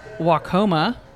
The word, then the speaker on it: Waucoma: So then we rolled on from there to Waucoma, which I had to double check the pronunciation of, and I think I'm good. – Yeah, you nailed it.